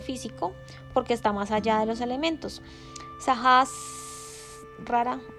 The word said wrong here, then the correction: Sahasrara: lo pronuncié mal, Sajas rara, rara,